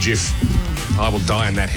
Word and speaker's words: GIF: And so will the guy who invented them, who insists that it is pronounced Jif. – Yeah. – Now, people are flying in and come back, it's a GIF.